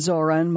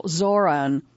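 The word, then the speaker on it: Zohran: Zoran Mamdani. Mamdani pronounces his first name with a slightly aspirated H, and emphasis on the second syllable. It's a pronunciation he came to favor over the more casual Zoran,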